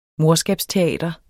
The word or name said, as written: morskabsteater